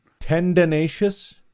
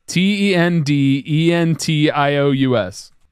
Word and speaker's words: tendentious: How do you say that word? I don't know what word you're saying. – Tendinous. T e n d e n t i o u s. – Tendentious.